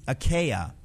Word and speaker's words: Achaia: How do you pronounce A-C-H-A-I-A from 1 Corinthians? Is it Greek or Latin? What name is that? – Achaia. – Achaia. – Achaia from 1 Corinthians is Greek.